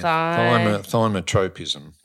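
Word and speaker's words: thigmotropism: So it's – how do we say it? Thymotropism. Thymotropism. – Yeah. Thymotropism.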